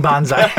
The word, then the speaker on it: Buckaroo Banzai: Did you say that right? Buckaroo Banzu. – Buckaroo Banzai.